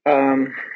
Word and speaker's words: Weyl: So his name is spelled W-E-I-L. And in French tradition, we pronounce it Weil. – Oh, okay. So that's my mistake. – Whereas Hermann Weyl, last name is spelled W-E-Y-L. And in the German tradition, we say Weil.